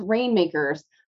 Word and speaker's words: RNMKRS: You know, it's pronounced Rainmakers, but it's spelled without any of the vowels. So it's RNMKRS.